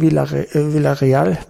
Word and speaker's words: Villarreal: Es der Auftritt bei Villarreal heißt es ja, genau ausgesprochen, erinnerte mich an die Spielweise des Hamburger SV.